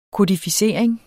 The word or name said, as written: kodificering